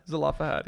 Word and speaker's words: Zelophehad: Zelophehad, yeah.